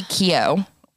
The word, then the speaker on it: Keough: Riley Keough. Is how you say her last name.